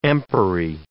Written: empery

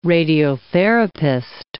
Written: radiotherapist

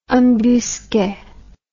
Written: embusque

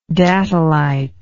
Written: datolite

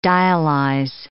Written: dialyze

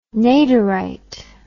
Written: Naderite

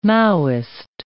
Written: Maoist

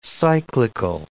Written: cyclical

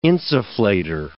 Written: insufflator

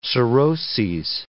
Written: cirrhoses

